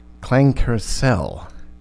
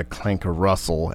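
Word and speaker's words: Klangkarussell: last week I mispronounced this artist's name as Clankercell, not uh, Clanker Russell as it looks like, or, or Clanker.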